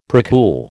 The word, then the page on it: precool: American English